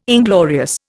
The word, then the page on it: inglorious: Indian